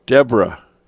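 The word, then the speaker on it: Debora: Deborah. It rhymes with zebra.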